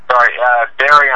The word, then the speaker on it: Barrie: You said Bury. Is it spelled B-U-R-Y or, how's this spelled? – Barrie, Ontario. B-A-R-R-I-E. – Okay, Barrie, Ontario. And it's a mile north of, Toronto?